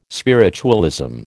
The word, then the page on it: spiritualism: American English